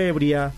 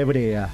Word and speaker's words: hebrea: Porque no es lo mismo decir, este relato corresponde a una vieja leyenda ebria, que este relato corresponde a una vieja leyenda hebrea. A tener en cuenta, y hay que pronunciar las cosas como corresponde.